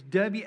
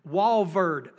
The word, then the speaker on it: Walvoord: There was a commentator it was Walorovh or something. I don't know how to say his name. Walvoord.